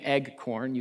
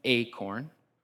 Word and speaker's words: acorn: So in the future, if you are corrected for saying egg corn, you cannot blame me because I'm telling you now it's acorn.